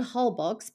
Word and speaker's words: Holbox: Holbox, spelled Holbox but pronounced Holbox, is incredibly beautiful.